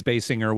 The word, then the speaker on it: Basinger: Again, Basinger, is it Basinger or Jer? Is it a I think it's Basinger with a hard G. I think so, but don't quote me.